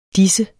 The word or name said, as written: disse